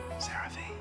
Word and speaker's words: CeraVe: CeraVe.